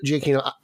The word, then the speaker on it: Giacchino: But I really like, I'm going to butcher his name, Giacchino. I think that's not how you pronounce it. – Yeah, that's it. – Oh, is it? Giacchino. I really love what he did with Spider-Man.